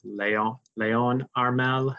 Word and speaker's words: Harmel: So I think in French it'd be properly pronounced Leon Harmel, but I'll just say it in English.